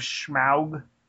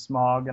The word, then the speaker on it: Smaug: But I will say that even though this movie, The Desolation of Smaug, which I always thought was Smog, and I felt terrible that I've been saying it wrong, I guess.